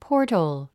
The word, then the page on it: portal: pɔ́ːrtl; ポータゥ